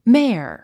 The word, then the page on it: mare: méə r; メェア